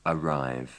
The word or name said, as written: arrive